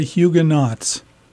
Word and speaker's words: Huguenots: We would say Huguenots. – Okay, so I'm not even saying it right. – No, that is really, that is not kind to the French, is it? – No. – It's Huguenot.